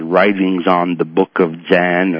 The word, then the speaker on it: Dzyan: And this was called the Book of Zan, spelled D-Z-Y-A-N. And I'm not sure of the pronunciation since I learned these things myself. And if I was subject to a more formal theosophical education, they may pronounce that term differently. But I'm used to saying the Book of Zan, D-Z-Y-A-N.